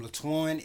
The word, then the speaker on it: Latwan: It's under the name Latwan, LATWAN.